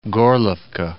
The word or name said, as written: Gorlovka